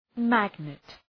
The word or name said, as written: magnet